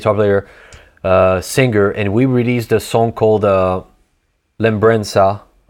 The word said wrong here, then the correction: Lembrança: That was really American for me to pronounce it like this. Lembrança, it's in Portuguese.